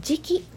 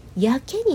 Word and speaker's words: 自棄: はい、自暴自棄ちょっと頭の中にすぐ浮かぶでしょうかね自暴自棄の「時期」を書いて「時期」になるではなくて「やけ」になると読む